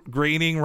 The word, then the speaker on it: Groening: he said an internal joke they had was uh, Groening rhymes with complaining.